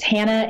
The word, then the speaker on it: Hanna: My name is spelled a little bit different than most, but it's Hannah, H-A-N-N-A, Bearcat. Which is B-A-R-A-K-A-T, and they can find me on all the things.